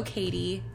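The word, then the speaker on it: Katy: If you type Hello Katie, that'll pop up. Hello K-A-T-Y, I will say. My name is spelled with a Y.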